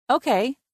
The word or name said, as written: Okay